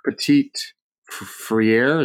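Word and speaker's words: Petit-Frere: Nick Petit Friere. Is that how you say it? – That is how you say it.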